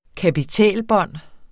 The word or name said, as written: kapitælbånd